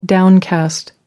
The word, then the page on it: downcast: American English